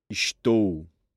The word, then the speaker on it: Estou: And notice the OU sound on the last syllable. – Estou.